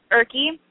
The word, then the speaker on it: Ihrke: Erke. I-H-R-K-E. – I was just dropping the E at the end. I was calling you Erk.